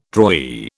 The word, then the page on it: drawee: British English